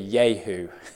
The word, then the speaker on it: Jehu: Jehu. By the way, the literal translation, the literal pronunciation of his name would be Yehu.